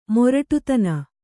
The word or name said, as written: ಮೊರಟುತನ